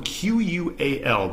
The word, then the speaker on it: qual: And the last one I got on the list is the L a lot of times is silent at the end of words in Portuguese. So the word qual is it's it's spelled Q U A L, but it's pronounced basically basically like qual.